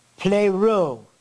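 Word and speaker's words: plero (πληρόω): is a word that is pronounced, that it says, here we go, plero. That was pretty awesome, wasn't it? P-L-E-R-O-O.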